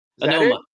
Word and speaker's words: Anoma: Anoma, yes.